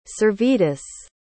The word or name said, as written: Servetus